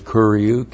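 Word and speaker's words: kuriou (κυρίου): And the genitive is kuriou, K-U-R-I-O-U.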